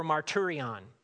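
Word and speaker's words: marturion (μαρτύριον): In the Greek, it's pronounced martyreo or marturion. It's where we get the word martyr from.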